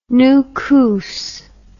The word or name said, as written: Nukus